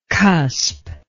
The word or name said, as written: cusp